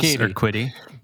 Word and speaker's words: Urquidy: Urquidy. – Urquidy. Close. Urquidy.